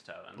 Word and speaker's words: Tevin: It's Tevin. – Like Tiva, but with an N? – at the end?